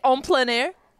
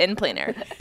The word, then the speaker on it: en plein air: I'm trying to say it in French, how they say it. En plein air.